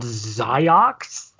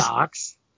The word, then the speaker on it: Ziox: Zyox. – Of course it is. – Zyox. Z-I-O-X.